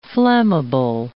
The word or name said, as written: flammable